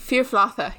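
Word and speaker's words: fír flatha: And maybe build something around a king who's lost his Firflotha? Is that how you say it? – Firflotha, yes.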